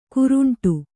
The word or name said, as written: ಕೋರಂಟಿ